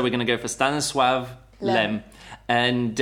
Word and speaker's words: Stanisław Lem: We're reviewing His Master's Voice by Stanislaw Lem, and you pronounce it Stanisław Lem. – Stanisław is a yeah. Stanisław Lem. He's Polish. – It's Polish. – It's got that little thing through it.